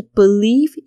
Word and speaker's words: believe: 好，接下来就是 believe you can have it，believe b e l i e v e believe。就是说，只相信。那这个字呢，有些人会念成 believe 或 believe 哦，就是那个 b e 的声音呢，稍微轻一点。重点是在那个 leave 上面。